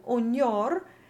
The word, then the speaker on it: ognor: In ognor, we had said in the last Italian episode that this N with the tail on the left is always doubled when it's between two vowels.